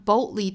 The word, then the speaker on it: boldly: 美音的话呢， boldly 这个词更倾向于会把里面的这个的发音给憋掉，也就是会形成一个 glottal stop 的发音。 boldly 相当于这个的发音是被吞掉了的。 boldly 声音在这两个音之间会顿一下，所以相对来说美音在发这个词的时候要比英音要简单一些哈，因为它其实就是图省事，然后把这个音给略掉了。